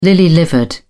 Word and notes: lily-livered: British English

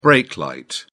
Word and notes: brake light: British English

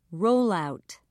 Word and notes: rollout: British English

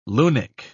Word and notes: lunik: British English

